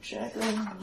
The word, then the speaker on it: Jagrin: It's certainly possible it would be old Jagrin. – How do you spell it? Graf with a T. – Yeah, J-A-G-R-I-N. – Yeah, I figured Jagrin.